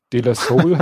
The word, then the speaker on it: De La Soul: De La Soul habe ich. Ist aber De La Soul ausgesprochen, oder? De, De La Soul, genau.